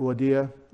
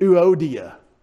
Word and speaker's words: Euodia: I bet that was pretty the way her mom and dad said it. Euodia, Euodia, Euodia. Probably something fast like that. We read it Euodia. Oh, Euodia. And I encourage, this is going to be harder for you, S-U-N-T-U-C-H, long E, Syntyche.